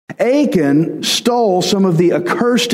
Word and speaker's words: Achan: Man, I don't forget Achan because his name sounds like the word aching, like my back does.